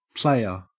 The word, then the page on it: playa: Australian English